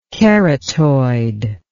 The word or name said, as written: keratoid